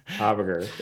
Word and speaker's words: Habiger: Habiger, man. Habiger, yeah.